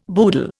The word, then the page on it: boodle: Indian